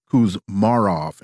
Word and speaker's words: Kuzmarov: I mispronounced your last name. It is Kuzmarov, and I apologize for that mispronunciation.